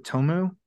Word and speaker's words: Tomeu: Is it Tomu Mori? – Sure. – T-O-M-E-U Mori.